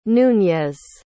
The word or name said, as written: nuñez